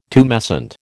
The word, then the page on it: tumescent: American English